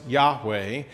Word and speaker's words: Yahweh: We think it was probably pronounced Yahweh, and it means I am.